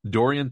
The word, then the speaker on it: Dorian: What did you say his name was? Darian or? – Oh my gosh, I have it up right here. Hang on. It's Dorian Thompson Robinson.